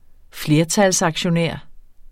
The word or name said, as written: flertalsaktionær